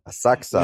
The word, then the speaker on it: Asakusa: Damn. Asakusa. I butchered that. Asakusa. Yeah, okay.